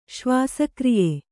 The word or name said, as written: ಶ್ವಾಸಕ್ರಿಯೆ